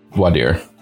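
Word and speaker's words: voir dire: Voidire, I think, is how it's said in, like, English pretending to be French. – I see. – Voidire.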